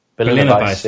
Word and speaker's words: Berliner Weisse: It is a peach and raspberry I never know how to say this. Really? Berliner Weisse? – Berliner Weisse, yeah.